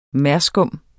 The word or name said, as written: merskum